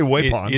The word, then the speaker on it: Waupun: And, man, he had quite a show to put on, not only for his own contingent that came out from Waupun, Wisconsin. Am I saying that right? – I don't know. Waupun or Waupun? I would say Waupun.